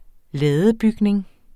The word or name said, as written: ladebygning